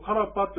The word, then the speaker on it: karpa: このカラパというやつもどういうふうな文字になるかというと、ローマ字で書きますと、 K-A-R-P-A ですね。 R の発音ですね。 K-A-R-R-P-A。カラパ。ですから、カラパじゃなくて、カラパ。というふうに言うと、これは発音できます。